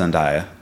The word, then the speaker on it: Zendaya: I think it's pronounced Zendaya.